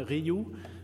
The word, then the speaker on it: Rioux: So my name is Martin Ryu. Pronounce it like Eugene Ryu from the Dodgers. So it, in French, R-I-O-U-X is like the letter Y.